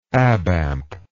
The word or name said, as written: abamp